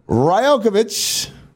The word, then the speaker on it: Rajaković: Yes, head coach of the Toronto Raptors, Darko Rajaković.